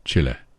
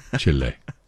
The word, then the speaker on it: Chile: And my friend Harold says I have to pronounce it Chile. Chile, not Chile. Chile. He's from Uruguay.